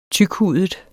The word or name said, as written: tykhudet